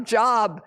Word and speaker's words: Job: His name is spelled J-O-B. It's not Job, it's Job. And Job, you know, as you read his story, he thought he had God pretty figured out.